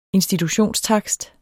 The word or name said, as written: institutionstakst